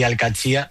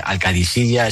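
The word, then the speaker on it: Al-Qadsiah: Bueno, se suele decir Al-Katsia, pero también hay gente que lo dice Al-Kadisiya. Es que es difícil, ¿eh? Ah, no es fácil, pero Al-Katsia es lo más fácil.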